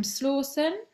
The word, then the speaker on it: Slawson: Today I'm really buzzing to have a guest on, Adam Slawson.